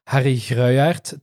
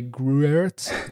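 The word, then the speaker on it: Gruyaert: I like flicking through Harry Gruyert, to pronounce it properly, or Harry Gruyert, to say it in English.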